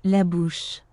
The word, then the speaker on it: La Bouche: La bouche.